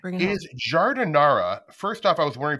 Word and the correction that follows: jardinera: Am I saying it right? – Jardinera? – Jardinera. – Jardinera, yes. – Like the air that we breathe, jardinera.